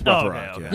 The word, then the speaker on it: Rothrock: It's Rothrock, I was like, shit.